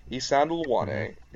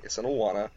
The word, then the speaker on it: Isandlwana: Isandlwana, it's a very hard word to pronounce, Isandlwana, Isandlwana.